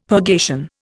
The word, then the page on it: purgation: Indian